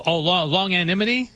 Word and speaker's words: longanimity: Oh, longanimity? – Longanimity.